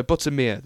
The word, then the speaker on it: Buttermere: It's located in Cumbria and it runs north out of Keswick towards Buttermore. Oh no, Buttermere. There we go. I said it wrong.